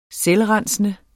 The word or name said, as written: selvrensende